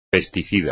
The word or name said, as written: pesticida